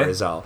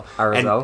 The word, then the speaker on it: Arizal: A-R-I-Z-A-L. – Okay. – Arizal. – Arizal.